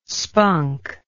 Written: spunk

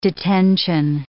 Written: detention

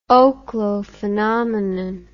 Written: oklo phenomenon

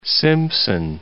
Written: Simpson

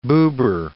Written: Buber

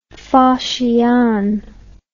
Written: faxian

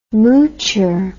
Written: moocher

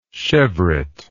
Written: cheveret

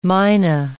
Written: mina

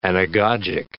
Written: anagogic

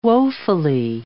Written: woefully

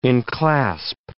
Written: enclasp

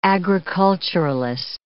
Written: agriculturalist

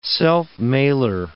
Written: self-mailer

